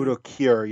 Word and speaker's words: Udo Kier: It's either Ice-T, Henry Rollins, or Udo Kier. Is that how you say his name? – Udo Kier, yeah, Udo Kier.